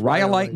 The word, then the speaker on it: rhyolite: Okay, rhyolite, Nevada.